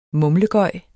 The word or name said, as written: mumlegøj